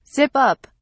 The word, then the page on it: zip up: British English